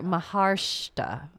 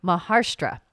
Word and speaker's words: Maharashtra: So Uttara was born in Nagpur, Maharashtra. Oh, I've said that wrong. Maharashtra. There we go.